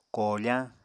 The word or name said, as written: koofañ